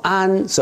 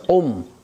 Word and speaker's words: Om: Om 不要讀作安，是 Om Om Om 啊，萬生之母，萬善之源。